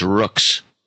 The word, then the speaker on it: Roux: It's pronounced Rooks.